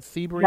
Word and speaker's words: Sebring: Did I say it right? Sebring? – Right.